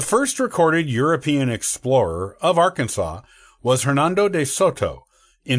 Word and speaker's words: Arkansas: Interestingly, the pronunciation of the state was only settled as Arkansas with an act of the state legislature in the 1880s. Before that, it was more commonly pronounced as Arkansas. And some local people still use that pronunciation for the nearby Arkansas River.